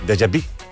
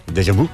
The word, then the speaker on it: Déjà vu: Déjà vu. – Déjà vu. – Déjà vu. – Jo dic sempre déjà vu.